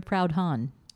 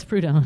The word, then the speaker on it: Proudhon: So then What is Property by Monsieur Proudhon? – Yes. Is that how you say it? – No, it's Proudhon.